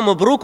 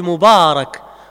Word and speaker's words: mubarak: On ne dit pas baraka, on dit baraka, on ne dit pas mubruk, on dit mubarak.